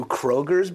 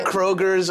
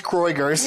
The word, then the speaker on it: Kroger's: Here Kroger's over LaCroix? I'm telling you. – I've never even, like, the grocery store? – First of all, it's pronounced Kroiger's.